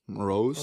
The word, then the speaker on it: Moroz: And then what's the last name? Moroz? – Moroz. Oh, got that right.